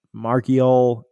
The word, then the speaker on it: Marchiol: I don't want to butcher his last name, but Nico Marchiol, M-A-R-C-H-I-O-L, Marchiol, Nico Marchiol. That's what we're going to go with here.